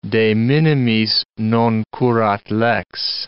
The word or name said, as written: de minimis non curat lex